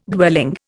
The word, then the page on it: dwelling: Indian